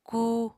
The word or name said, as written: kuu